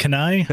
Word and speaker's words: kunai: It might be pronounced kunai? Like a little bit shorter.